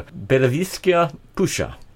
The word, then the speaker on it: Беловежская пуща: Now I'm going to try and pronounce that again with help from Jana. Belavizhskaya Pusha. – Yes, that's right.